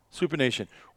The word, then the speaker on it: supination: Supination. Wow. – S-U-P-I-N-A-T-I-O-N, which is the opposite of pronation.